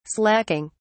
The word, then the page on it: slaking: British English